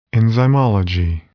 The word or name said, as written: enzymology